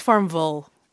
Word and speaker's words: Farmville: You kind of got to say it like Louisville. – Farmville. – That's right.